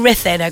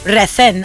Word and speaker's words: Ruthin: Now, that's in Ruthin, okay? – Ruthin. – And I only found out you pronounce Ruthin and not Ruthin not too long ago.